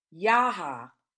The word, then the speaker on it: Yeehaw Junction: You know, it's Yeehaw Junction, but it's Yaha. Really, that's how you pronounce it, which means wolf.